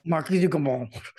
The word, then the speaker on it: Marquis de Grammont: Anyways, did I say it correctly? Marquis de Grammont. – Marquis de Grammont.